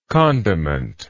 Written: condimentary

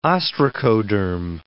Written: ostracoderm

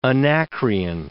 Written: Anacreon

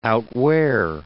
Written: outwear

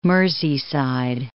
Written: Merseyside

